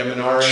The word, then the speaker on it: Minari: Oh, Minari? – Yeah, Minari.